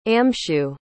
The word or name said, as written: amshu